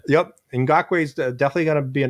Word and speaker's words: Ngakoue: That's Yannick Ngakoue.